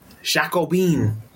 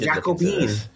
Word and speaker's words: Jacobin: Any thoughts before we go to the Jacobin, if that's how you say it? – Jacobin. – Is that how you say it? – Because yeah, you don't know your French Revolution? – I did Jacobin.